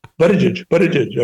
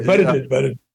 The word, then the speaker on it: Buttigieg: I said, "Buttigieg." It's Buttigieg. Okay, Buttigieg.